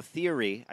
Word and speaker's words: Thierry: This report comes from Automotive News, and it talks about theory, the CEO theory, I guess that's how you say his name, T-H-I-E-R-R-Y, Bolor's latest comments.